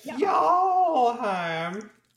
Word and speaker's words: Fjallheim: And you guys are headed for Fjallheim. – Fjallheim. – It's a soft J.